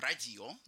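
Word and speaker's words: radio: Ударение всегда на предпоследнем слоге. Вот вы сказали «радио», а нас правильно «радио» говорить, так. Предпоследний слог.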